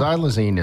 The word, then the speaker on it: xylazine: It's spelled with an X, yes. – And it's xylazine. – It's called Trank. That's its street name. So, that's what we're going to talk about today. Well, xylazine is a non-opiate sedative analgesic and muscle relaxant, and it's used for veterinary purposes, kind of like a horse tranquilizer.